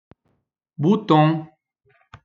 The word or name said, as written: bouton